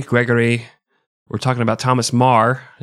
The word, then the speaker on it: Meagher: And for our listeners who don't speak Gaelic or aren't from Ireland, it's not spelled M-A-R like you think it would be, and it's not pronounced meager like you think it would be, but it is spelled M-E-A-G-H-E-R.